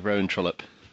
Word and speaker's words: Rowan Trollope: Is it Rowan Trollope? Is that the correct pronunciation? That's exactly right, yes. Yeah, Rowan Trollope.